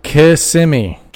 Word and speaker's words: Kissimmee: When you look at the name, I know the first four letters says kiss. Don't pretend it's not a word. Say Kissimmee.